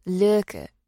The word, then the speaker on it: Lykke: You'd be as surprised as I was to learn that, because of its Danish origin, the word is pronounced Lykke.